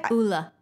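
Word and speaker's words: Ole: So it's the Uli Henriksen, or how do you say it? – Ula. – Ula, yep.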